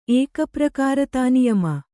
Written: ಏಕಪ್ರಕಾರತಾನಿಯಮ

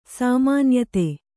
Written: ಸಾಮಾನ್ಯತೆ

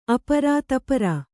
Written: ಆಪೂರ

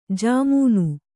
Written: ಝಮ್ಮನೆ

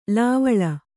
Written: ಲೇವಳ

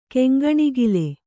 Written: ಕೆಂಗಣಿಗಲು

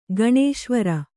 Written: ಗಣೇಶ್ವರ

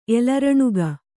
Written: ಎಲರುಣಿಗ

